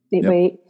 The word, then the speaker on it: 8x8: Is that how you pronounce it? – Mm-hmm. – 8x8.